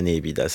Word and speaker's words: nepita: Mais nous, on dira que c'est la néhébida, ça ne ressemble à rien d'autre. – Ça s'écrit N-E-P-I-T-A.